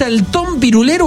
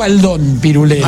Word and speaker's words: Antón Pirulero: ¿Es Antón Pirulero o Aldón Pirulero? – Aldón. – No, Aldón. – Antón. Entonces lo dije bien. – Pirulero, Antón, lo dije bien. – Cuya historia no es apta para menores. – Toda la vida le dije Aldón. – Yo también. Aldón. – Pero es Antón, que es un hombre que asesinó a su esposa.